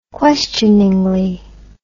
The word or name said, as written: questioningly